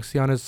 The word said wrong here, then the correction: Senua: Sacrifice. It's called. How do you say that, Mikey? Senua's Saga. Thanks.